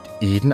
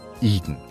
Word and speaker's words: Eden: Der wird auch nicht Eden ausgesprochen, sondern englisch Eden.